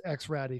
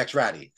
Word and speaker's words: XR4Ti: Heroic fix went to Project Mercur's space program, Mercur XR4TI. I think I didn't remember here the I'm sorry, I think you mispronounced X Ratty because they're listeners. – Oh, the X Ratty.